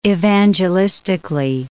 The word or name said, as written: evangelistically